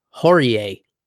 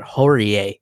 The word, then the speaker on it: Horie: Hakeem Dawodu, nasty head kick knockout over Yoshinori Horie. I would have swore it was Hori, because that sounds like a more Japanese pronunciation, but Horie, Horie.